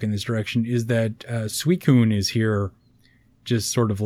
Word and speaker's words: Suicune: Suicune.